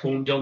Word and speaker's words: Holmdel: And probably the coolest one for me is Peter Criss getting up on stage at a Rob Zombie concert in Holmdel, New Jersey. You tell me how to say it. That's your neck of the woods. – That's correct. Holmdel, New Jersey, the PNC Bank Arts Center.